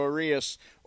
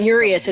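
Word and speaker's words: Urías: And by the way, don't sleep on World Series hero Julio Urias or Dustin May in that it's rotation. It's not Urias, it's Urias.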